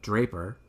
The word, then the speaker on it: Draper: Unbeknownst to the couple, before they left, Draper had unlocked the door to the basement. – So which rhymes with Raper, by the way.